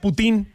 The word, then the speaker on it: poutine: Se escribe poutine. – Ahí está el poutine, uh-huh. Poutine, pero se pronuncia putín.